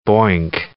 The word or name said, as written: boink